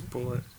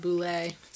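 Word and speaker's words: Bulleit: Well, no, it's spelled weird, but it's also from Kentucky, so I'm pretty sure it's not Boulay.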